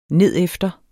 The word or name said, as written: nedefter